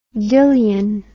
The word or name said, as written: Dillion